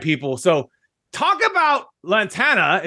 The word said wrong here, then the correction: Latana: So, you know, at Latana, we are a B2B SaaS company.